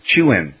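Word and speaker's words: Chuen: I don't know how to do the emphasis on the thing, but it's C-H-U-E-N. – Chewin. – Hi, RSB. My name is pronounced Chewin.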